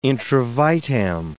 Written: intravitam

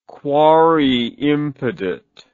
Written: quare impedit

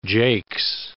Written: jakes

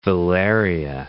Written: velaria

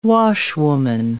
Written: washwoman

